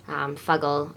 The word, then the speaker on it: Fuggle: Fuggle, obviously, rhyming with muggle.